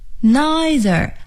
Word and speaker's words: neither: 既不，首先我们可以说 neither， N-E-I-T-H-E-R， neither， neither， I 的音啊， neither。美音发音可以是 neither neither，这是既不怎么怎么样。